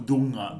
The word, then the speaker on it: dunha: Dunha se pronuncia como em português ou o quê? Ou seja, dunha... – Não. – Não, é um N velar. É um, unha, o dunga, dunga.